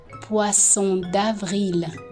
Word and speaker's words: poisson d'avril: How do you pronounce this? – poisson d'avril.